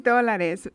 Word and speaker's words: dólares: Oh, dólares. Tres dólares. Pero dolores, sometimes we say it like just in a funny way to say dólares.